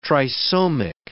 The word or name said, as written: trisomic